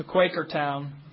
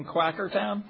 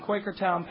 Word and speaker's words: Quakertown: It's not convenient to drive 6 hours to Quakertown. Which, by the way, I've been pronouncing Quackertown.